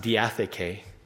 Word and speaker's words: diatheke: It's pronounced diatheke, as in he hath an AK-47 or something like that.